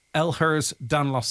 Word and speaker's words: Ehlers-Danlos: Thanks so much for coming in and helping us raise awareness of Ehlers-Danlos syndrome.